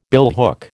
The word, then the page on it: billhook: American English